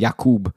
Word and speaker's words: Jakub: Apparently, he had an interview, and he was like, I like when they call me Jakub, because that's the actual way to pronounce it.